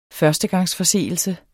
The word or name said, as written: førstegangsforseelse